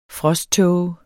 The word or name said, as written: frosttåge